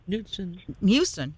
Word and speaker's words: Knudsen: K-N-U-D-S-E-N Huffman. – Newton.